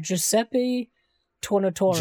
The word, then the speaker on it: Giuseppe Tornatore: And this is an Italian film, and it's directed by, if I can say his name, Giuseppe Tornatore. You could probably say it better. – Giuseppe Tornatore.